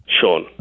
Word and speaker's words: Shawn: My name's Sean. – Sean. What's your real name, yeah. – Sean? – Sean. – Okay. How do you spell that? – S-H-A-W-N.